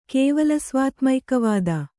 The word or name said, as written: ಕೇವಲಸ್ವಾತ್ಮೈಕವಾದ